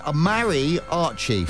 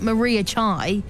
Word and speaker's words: mariachi: The band is called Mariachi, pronounced Mary, Mary Archie.